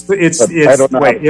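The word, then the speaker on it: Thwaites: I can't pronounce it. Thwait, I think. – Yeah, it's Thwait. If it's the Doomsday Glacier, it's the Thwait Glacier.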